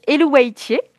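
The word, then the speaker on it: Eluveitie: Alors moi, je le prononce Elouaitier.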